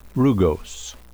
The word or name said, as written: rugose